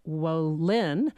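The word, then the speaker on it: Wolynn: And our guest is Mark Wolin. Accent on the second syllable.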